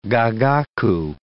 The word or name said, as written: gagaku